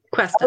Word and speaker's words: Cuesta: Cuesta.